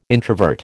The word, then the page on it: introvert: American English